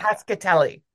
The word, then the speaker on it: Cascatelli: Is it Cascatelli? Is that how you say it? – Cascatelli, yeah. – Cascatelli.